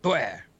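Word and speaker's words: Pu'er: So puer, is that? – Puer. – Puer. – Puer. – Is that correct? – Say it like you're French. Yep. Puer.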